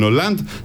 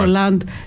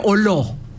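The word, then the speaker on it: Hollande: Τώρα που είπε Ολάντ, θα ήθελα να απευθυνθώ στους συναδέλφους που δεν είναι γαλλομαθείς ότι δεν τον λένε Ολό. – Ναι, εντάξει, δεν τον λένε Ολό. – Κάποιοι τον προφέρουν έτσι. – Όχι, δεν είναι Ολό.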